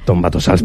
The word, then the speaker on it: Tombatossals: Bueno, y este Tombatossals, ¿lo he dicho bien? – Tombatossals, perfecto.